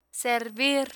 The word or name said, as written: servir